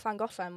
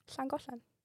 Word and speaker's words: Llangollen: We've got like Llangollen, which is like right next to where I live, which English people call it Llangollen because they can't pronounce this sound.